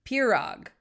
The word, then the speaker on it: pirog: I believe it's pronounced pirog. P-I-R-O-G.